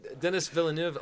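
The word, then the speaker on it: Denis Villeneuve: Did I say Dillis? Dillis. – That's definitely not how it was pronounced. – Dillis uh, Villajuego. – Um, no, uh, Dennis Villeneuve.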